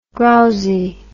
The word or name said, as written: growzy